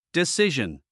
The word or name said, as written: decision